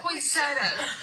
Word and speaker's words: poinsettia: Poinsettia. – Poinsettia. Poinsettia. I say poinsettia. Poinsettia, how do you say it? – It's poinsettia. It's French. – Poinsettia. – Poinsettia. – Poinsettia. How do you spell it? P-O-I-N-S-I-E-T-T-A.